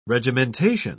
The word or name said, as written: regimentation